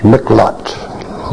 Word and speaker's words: miqlat: M-I-Q-L-A-T, pronounced miklot, lot.